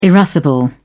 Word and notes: irascible: British English

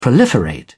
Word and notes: proliferate: British English

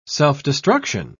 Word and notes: self-destruction: British English

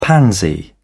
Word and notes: pansy: British English

